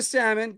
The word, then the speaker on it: salmon: Get the salmon. Get him in there. He's Sammy. That's that's what you call them. You can put the L in there, even though they don't pronounce it.